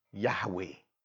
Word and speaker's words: Yahweh: Which actually the Jews, they don't know how to pronounce because it doesn't have a vowel. It's Y-H-W-H. So they have to put A and E in between to be able to pronounce it. Yahweh.